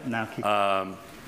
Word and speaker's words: Naoki: Naoki Higashida? – Naoki.